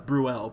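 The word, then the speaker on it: Brühl: So he gets her to go to this restaurant that's owned by uh, what's his name, Daniel Bruhl, who plays a uh, uh, Bruel, pardon me, I think is how you say it,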